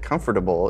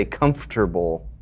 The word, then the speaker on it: comfortable: I just want to thank you, Lawrence, because you are one of the very few people that know that comfortable is a four-syllable word. Most people say comfortable.